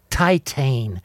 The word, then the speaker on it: Titane: All right, now, Titane. You got me to see Titane. – Or as it's pronounced in French, Titan.